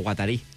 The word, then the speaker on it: Guattari: De Deleuze i Guattari. O Guattari. Ben pronunciat.